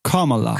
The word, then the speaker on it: Kamala: Und also insofern wähle ich jetzt auch hier Kamala. Nee, ich habe schon wieder falsch ausgesprochen. – Kamala. – Kamala. Kamala. So ein bisschen wie Camelot, ne? Daran kann ich mich orientieren.